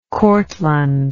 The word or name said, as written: cortland